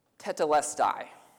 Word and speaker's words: tetelestai: And the Greek word here, see if I can pronounce it, is tetelestai.